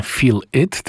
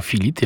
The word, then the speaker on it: Filit: A to Filit, i to tak pisze się, jak mówi, nie Feel It, tylko Filit, jako jedno słowo.